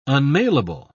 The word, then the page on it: unmailable: British English